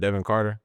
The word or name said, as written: Devin Carter